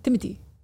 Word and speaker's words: Timothée: Wait, is that how you say it? – Yeah, there's no T. Timothy.